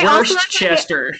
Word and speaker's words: Worcestershire: Emily, how do you say Worcestershire? – I think I say Worcester. – Oh, boy. Okay. – But I Worcestershire.